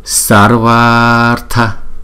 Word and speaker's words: Sarvartha: Chữ A cuối của chữ Sarva và chữ A đầu của chữ Artha ghép lại thành một chữ A dài. Đó, cho nên chúng ta sẽ đọc là Sarvartha Sadhani.